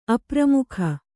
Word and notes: ಅಪ್ರಮುಖ: apramukha

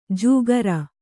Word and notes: ಜೂಗರ: jūgara

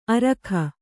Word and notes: ಅರೋಗ: kannada

